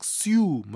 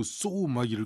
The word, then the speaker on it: sew: SEW 또 SOW 이거 읽으라 그러면 못 읽는 학생들이 많아요. 막 쓰유 막 이렇게 읽고 쏘막 이렇게 읽고 그러는데 둘다 발음이 똑같아요. 쏘 이렇게 돼요. 오우 발음입니다. 쏘 SEW나 SOW는 다 쏘 이렇게 하는데요. SEW는 바느질하다. SOW는 씨를 뿌리다 이런 단어예요. 외우기 쉬워요. So, SOW의 O가 동그란 C라고 생각하시면 돼요. 근데 이게 발음이 똑같은 게 주의사항이죠.